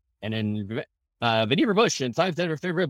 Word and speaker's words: Vannevar: Oh, by the way, By the way, just so you know, on the trivia, it's Vannevar.